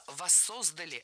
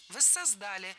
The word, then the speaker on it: воссоздали: Много раз прозвучало «воссоздали», но это неверно. Правильный вариант – «воссоздали». В таких словах чаще сохраняется ударение начальной формы.